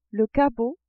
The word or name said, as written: cabot